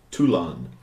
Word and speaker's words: Toulon: Toulon? – Toulon. – Spell it. – T-O-U-L-O-N.